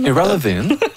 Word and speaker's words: Ira Levin: Je ne sais pas comment on prononce en anglais. Ira Levin. – Ira Levin. – Où ça sonne irrelevant un peu. – C'est peut-être irrelevant.